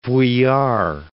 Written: Vuillard

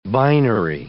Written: binary

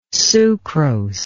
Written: sucrose

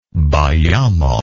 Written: bayamo

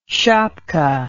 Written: shapka

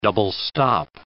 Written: double-stop

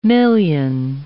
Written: million

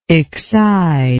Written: excide

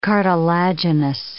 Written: cartilaginous

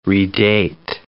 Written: redate

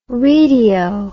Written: readeo

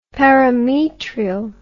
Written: parametrial

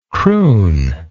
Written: crooningly

